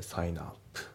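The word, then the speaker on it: sign up: で、発音ですけど、サインアップのところがリンキングでサインアップ、あとアップオンのところもリンキングでアパーンで、あとオンアワーのところもリンキングでオーナーですね。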